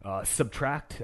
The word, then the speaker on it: SBTRKT: Subtract. That's spelled SBTRKT.